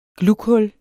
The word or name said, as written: GLUGHULLERNES